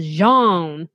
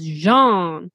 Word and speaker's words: genre: Um, he just pr- he pronounces it in the most French way possible, which is like dropping off the R and the E, and so he says genre instead of genre.